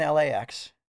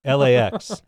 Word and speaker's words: LAX: LAX, not L-A-X. – LAX. – Now, that LAX. Is always on the A for some reason.